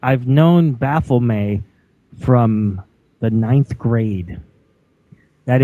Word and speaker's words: Baphomet: As far as I know, Baphomet, and my wild friends from Windermere, Pennsylvania, Lucas, Don Reese, and others, Mike Corsi, James Cabetta, have all read the Satanic Bible by Antoine Sanzer-Lavey and said that that is pronounced Baphomet.